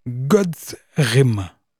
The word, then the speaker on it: Godthrym: Il s'appelle God's Rim. J'ai beaucoup de mal à prononcer le nom du groupe. Sa s'orthographie G-O-D-T-H-R-Y-M, God's, God's, God's Rim.